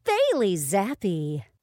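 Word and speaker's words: Bailey Zappe: Bailey Zappy. – Bailey Zappy.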